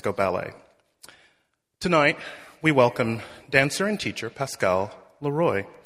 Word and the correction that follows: Leroy: or pronounced correctly, Leroy. I'm working on my French pronunciation.